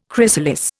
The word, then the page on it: chrysalis: Indian